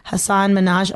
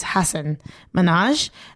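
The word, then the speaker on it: Hasan Minhaj: There's also like this thing of like people don't get like non-white names properly, like Hassan Minhaj. Oh boy, I think I said his name wrong though. It's Hassan Minhaj.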